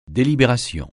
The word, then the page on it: délibération: français